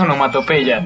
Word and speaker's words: onomatopeya: Onimatopeya. Perdón, eso es onomatopeya.